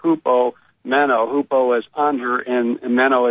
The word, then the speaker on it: hupomeno: There's the Greek word hupomeno, which that's in the Koine pronunciation,